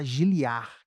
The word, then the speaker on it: Giliardi: E para fazer dobradinha com o pastor Kerly, pastor, meu nome também é mó confusão, porque é para se pronunciar giliar, que o D é mudo, tipo recó mas aí ninguém me chama, é só Giliardi.